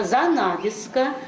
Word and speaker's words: занавеска: Ударение на занавеска.